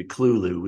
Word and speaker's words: Cthulhu: It's supposed to be Clulu. We're supposed to say it.